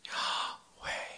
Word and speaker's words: Yahweh: Is Yahweh, and that it's YHWH, but it has no vowels, and that the proper way to pronounce it in Hebrew is Yah, you breathe in, and Way, you bring out. So it's Yahweh, and it's Yahweh.